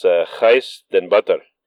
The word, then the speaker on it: Gijs den Butter: My guest today, it's a difficult name for a lot of people to pronounce, but I'll do my best. It's Gijs den Butter. He's a Dutch guy. We're talking about his company, SenseGlove. So, Gijs, how are you doing? – Thank you, for the introduction. Oh, well, good pronunciation of my Dutch name.